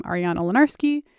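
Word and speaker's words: Ariana Lenarsky: And you can probably Google What's Your Deal or my name, Ariana Lenarski.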